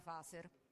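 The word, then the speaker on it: Phaser: Prima di tutto, in caso servisse, Faser si pronuncia Faser.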